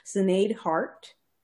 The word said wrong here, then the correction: Sinead Harnett: Harnett? Sinead Harnett? – Yes. Harnett? Oh, I said Hart. – Net? – I even read it wrong.